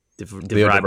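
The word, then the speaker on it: De Vries: Leo DeVry, I believe, is how it's pronounced. Anyone know? – Pat says yes. Yeah. I still haven't looked up the video pronunciation because I haven't taken it. It's Leo and then D-E space V-R-I-E-S. Yeah. DeVry.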